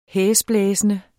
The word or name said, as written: hæsblæsende